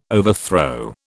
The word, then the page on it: overthrow: British English